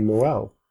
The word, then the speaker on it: Noël: And the umlaut it's not really an umlaut, but basically it's a mark that says that the two vowels have to be pronounced separately. Anyway, I thought that was kind of a neat thing. – Yeah. – And that's the way that tremas is used in French. That's why you say Noël and maïs for corn.